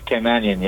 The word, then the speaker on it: Caymanian: How do you pronounce it? They say, so you're Caymanian? – Caymanian, yeah.